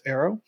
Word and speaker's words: ERO: It's spelled E-R-O, but it's pronounced Aero.